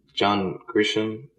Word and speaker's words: John Grisham: You know, John Grisham, is that how you say his name? – Yeah.